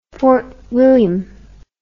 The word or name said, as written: Fort William